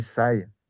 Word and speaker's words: Sigh: On voit Sai, le groupe japonais Sai, Mmh. – Euh, on prononce Sai, hein, S-I-G-H, hein, Sai qui lui euh, l'a fait, mais, mais, bon, il, est aussi, il met beaucoup d'avant-garde dedans.